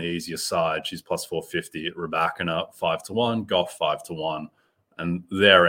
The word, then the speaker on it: Rybakina: And I'm all in Elena Rybakina. Have we ever figured out the pronunciation? I feel like we've just gone back. – Yeah, no, that's it. That's it, Rybakina.